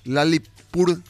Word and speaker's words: Lalitpur: Lalitpur, Nepal.